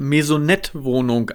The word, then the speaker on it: Maisonette: Nicht falsch verstehen, aber meine Frau sagt, wenn die Kinder ausziehen, dann ziehen wir in eine Marionettenwohnung und jetten durch die Welt. – In eine Marionettenwohnung, eine Maisonette-Wohnung. – Ja, ja, Maisonette-Wohnung. Okay, ich habe das falsch ausgesprochen.